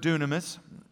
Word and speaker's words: dunamis: It's the word dunamis. It's spelled D-Y-N-U-M-I-S. I spell that to you because when you see the D-Y, we think die, but it's actually said in the Greek, do, all right?